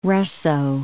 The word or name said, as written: Russo-